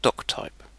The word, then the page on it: doctype: British English; [ˈdɒk.ˌtaɪp]